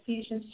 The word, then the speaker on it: Ephesians: Ephesians chapter 6, verse, did I say it right? Yeah, Ephesians chapter 6, verse 4.